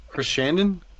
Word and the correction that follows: Sarandon: Chris Sarandon.